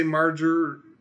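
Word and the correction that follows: Marjoe Gortner: It's Marjo Gortner.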